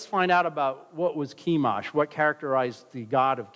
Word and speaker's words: Chemosh: There's other ways of pronouncing Chemosh. There's an interesting way that the Arabs pronounce it, but I don't remember. I was told that yesterday. Okay, the Moabites, Chemosh. So I'm going to say it the Anglo way, Chemosh.